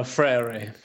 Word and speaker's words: Freire: Paulo Freire.